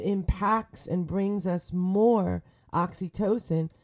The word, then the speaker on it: oxytocin: And we're going to talk a little bit about oxytocin. Let me spell that for you. Google it. O-X-Y-T-O-C-I-N.